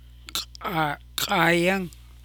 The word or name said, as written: Kaayang